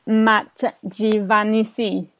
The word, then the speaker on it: Matt Giovanisci: Hi everyone, my guest today is the awesome and also creative Matt Giovanisci.